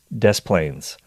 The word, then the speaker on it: Des Plaines: And Des Plaines, I don't know if I'm pronouncing that right. Is it Des Plaines, Illinois? Des Plaines. Okay.